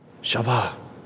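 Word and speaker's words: Chavah: I can just see him look over at her, maybe takes her hand, and he says, Shavah, Shavah. That's what Eve means. That's how you pronounce it in Hebrew.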